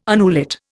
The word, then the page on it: annulet: Indian